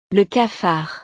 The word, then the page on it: le cafard: frances